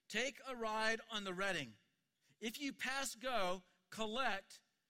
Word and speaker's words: Reading: It is Redding. So if you get nothing else out of coming today, you have learned how to say it's Redding.